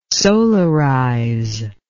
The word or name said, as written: solarize